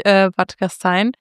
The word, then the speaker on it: Bad Gastein: Was jetzt genau Bad Gastein? – Mhm. – Nee, das ist ganz richtig, Bad Gastein.